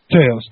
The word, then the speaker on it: Taos: Taos.